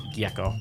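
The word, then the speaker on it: Gyecko: Gecko spelled with a Y. Y-E. G-Y-E-C-K-O. – Gecko. – So yeah.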